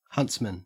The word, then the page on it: huntsman: Australian English